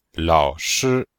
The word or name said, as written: lao3shi1